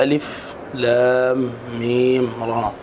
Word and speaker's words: المر: الله تعالى أعلم بمراده من هذه الأحرف، يعني تنطق هكذا ألف لام ميم راء.